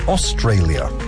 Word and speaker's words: Australia: La palabra en inglés en la que aparece tres veces la letra A, pronunciada de tres formas diferentes, es Australia. – La primera A suena como una O larga, la segunda como el diptongo A y la tercera suena como una A breve. – Australia.